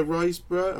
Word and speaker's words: Riseborough: Um, is it Angela Riseborough? Am I getting yeah. that name right? Yeah, yeah. Angela Riseborough.